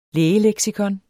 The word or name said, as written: lægeleksikon